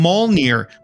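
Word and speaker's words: Mjölnir: It comes from Mjolnir, which is the name for Thor's hammer in Norse mythology.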